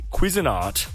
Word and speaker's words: Cuisinart: We have a Cuisinart.